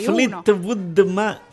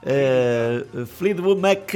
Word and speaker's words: Fleetwood Mac: Fleetwood Mac. – Questo qua mi piace. La pronuncia è fantastica. – Va bene, vada, ridica. – Fleetwood Mac.